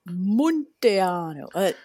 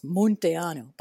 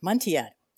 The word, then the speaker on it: Munteanu: It is meant to be in Romanian pronounced with that U the way it is, Munteanu, but I never pronounced it that way. I pronounce it Munteanu. I've anglicized it.